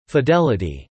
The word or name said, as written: fidelity